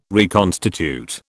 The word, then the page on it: reconstitute: British English